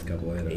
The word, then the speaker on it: capoeira: Yes, capoeira.